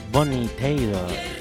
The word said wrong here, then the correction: Bonnie Tyler: O Bonnie Tyler, ¿cómo se dice? Bonnie Taylor. Bonnie Tyler. Sí. Bonnie Tyler.